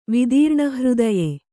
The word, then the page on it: ವಿದೀರ್ಣಹೃದಯ: vidīrṇa hřdaya